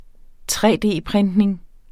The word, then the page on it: 3D-printning: tre-d-printning